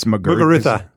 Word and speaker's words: Muguruza: It's Muguruza.